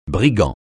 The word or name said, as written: brigand